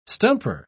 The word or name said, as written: stumper